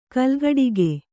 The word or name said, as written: ಕೋಲುಕುಟುಕ